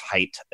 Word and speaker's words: Haidt: I think it's Haidt, how you pronounce it, H-A-I-D-T.